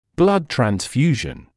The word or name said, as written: blood transfusion